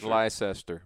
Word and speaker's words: Leicester: You spell that L-E-I-C-E-S-T-E-R. – Yeah. Looks Leicester. Like Leicester, but it's Leicester.